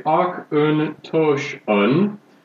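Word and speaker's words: Auchentoshan: People here say Ach Un Tosh Un.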